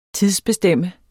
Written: TIDSBESTEM

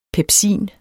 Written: pepsin

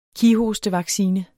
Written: kighostevaccine

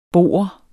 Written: boer

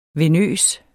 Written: venøs

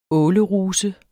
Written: åleruse

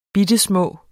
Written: bittelille

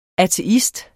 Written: ateist

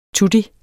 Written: tutti

